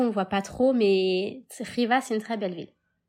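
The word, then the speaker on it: Khiva: Alors, ça s'écrit Xiva, mais ça se dit Riva.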